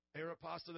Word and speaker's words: Aeropostale: Let's see, we got Aristopel, is that how you pronounce it? Aeropostale, then we got some expensive clothes.